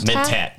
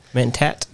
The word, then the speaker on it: Mentat: Are we saying that right? Mentals? – Mentat. – Mentat? – Mentat. – Mentat. Okay, a Mentat.